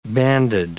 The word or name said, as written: banded